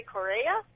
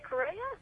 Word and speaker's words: Correia: It's by a guy named Larry Correa. – Sounds right. Correa. – Yeah, C-O-R-R-E-I-A. So I'm going to say Correa.